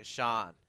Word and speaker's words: Sean: I'm Sean. How do you spell that? I'm like, S-E-A-N. S-S-H.